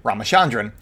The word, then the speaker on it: Ramachandran: But Janani Rama, I want to say it right, Ramachandran. That's it, Ramachandran. Ramachandran sounds better.